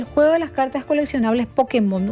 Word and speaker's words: Pokémon: Es Pokémon. Pokémon. O Pokémon. Bueno, lo cierto es que tiene acento en realidad.